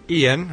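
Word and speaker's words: Ioan: And Loan Griffad. – Ian. I think it's just pronounced Ian.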